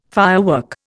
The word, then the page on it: firework: Indian